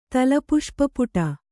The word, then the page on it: ತಲಪುಷ್ಪಪುಟ: talapuṣpa puṭa; Kannada